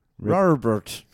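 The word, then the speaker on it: rarebit: It's R-A-R-E-B-I-T. – Robert.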